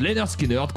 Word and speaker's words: Lynyrd Skynyrd: Lynyrd Skynyrd, comprenez, ça se prononce Lynyrd Skynyrd, puisqu'en lisant le nom du groupe, personne n'est capable de le prononcer correctement, n'est-ce pas.